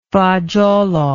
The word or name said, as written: fagiolo